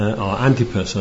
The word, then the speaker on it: Antipas: It talks about Antipas, or Antipas, I think it is probably better pronounced.